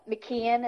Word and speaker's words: McKean: McKean, is that how you say his name? – Mm-hmm.